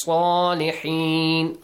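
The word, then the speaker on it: Salihin (صالحين): Kullum minas salihin. Saad has a heavy sound. Salihin.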